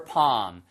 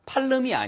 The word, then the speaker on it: palm: Someone who can, C-A-N. 그래서 I know someone who can read your palm. P-A-L-M이요. L이 묵음이에요. 이거 팔름이 아니에요.